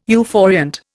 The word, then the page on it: euphoriant: Indian